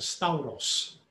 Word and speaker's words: stauros: The Greek word 4716, stauros. That's how you pronounce it, stauros.